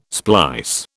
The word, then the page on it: splice: British English